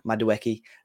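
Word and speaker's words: Madueke: But no, other than Nonny, how do you say Madjiouk? – Madueki.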